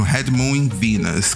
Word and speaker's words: Red Moon in Venus: É isso mesmo, Red Moon in Venus, que é Red Moon in Venus, da Kaliushis.